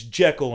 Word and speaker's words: Jekyll: It's Jekyll Island, right? – That sounds right.